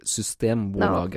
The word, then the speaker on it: Systembolaget: In Swedish, it would be Systembolaget. No, thank you. Except they pronounce the T because they're like that.